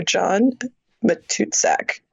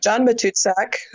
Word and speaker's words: John Matuszak: So John Matuszak. There it is. Yeah, is that it? – That's it. – John Matuszak,